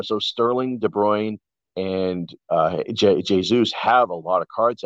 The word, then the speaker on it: Jesus: But Kevin De Bruyne, Raheem Sterling, Gabriel Jesus, it's actually pronounced Jesus, I believe.